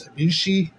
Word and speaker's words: Ibushi: I believe it's Ibushi is how it's pronounced.